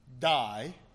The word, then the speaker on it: dei: Necessary comes from a Greek word, die. – 必须就是从那个希腊文的字那个 die 这里出来。 – It is spelled D-E-I, ironically. – 就是从 D-E-I 这个字出来。